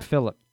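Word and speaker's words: Phillippe: He pronounces his name Philip.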